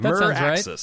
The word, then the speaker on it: Meraxes: Merexes. How would you pronounce that? Meraxes? – Yeah, that Meraxes. – Sounds right. Uh-huh.